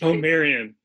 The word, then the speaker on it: Marion: And one example you give is this Marin drag line. Am I pronouncing it? The Marin drag line? – Marion.